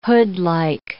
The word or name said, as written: hoodlike